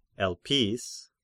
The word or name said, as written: Elpis